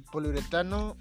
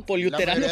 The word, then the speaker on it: poliuretano: el poliuretano pronunciado correctamente. Porque. Pues, poliuterano, sí, para empezar, güey, para empezar.